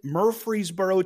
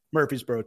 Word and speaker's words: Murfreesboro: Out of where I went to college in Murfreesboro, Tennessee, and yes, I do pronounce it Murfreesboro, Tennessee, while most people just say Murfreesboro, Tennessee. He might not have put up the most flashy stats, but he would fight for every yard that he got.